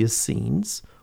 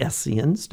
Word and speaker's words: Essenes: And there was a group of a religious group called the Essenes, or the Essenes, depending on one's pronunciation.